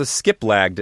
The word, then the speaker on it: Skiplagged: S-K-I-P. What is it? – Yeah, so SkipLagged is another one.